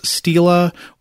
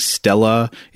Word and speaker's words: Stela: And I already apologize because I know at some point in this episode I'm going to forget to pronounce Stela with a long E and I'm going to start saying Stella. It's that mm-hmm. word, you know, it's S-T-E-L-A or S-T-E-L-E, which I can never get the sounds right in my brain.